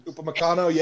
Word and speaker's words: Upamecano: Arsenal should go get either Koulibaly or Upamecano. I think that's how you pronounce his name. – Upamecano, yeah.